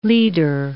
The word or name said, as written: lieder